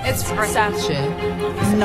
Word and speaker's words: Versace: It's Versace. Not Versace.